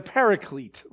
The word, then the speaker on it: paraclete: Uh, we like to say, we call it the, the, the, as we say it in the English way of pronouncing the Greek word, right? The paraclete, right?